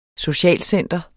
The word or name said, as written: socialcenter